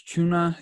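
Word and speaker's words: Cunha: I think it's Chuna is actually how you pronounce it.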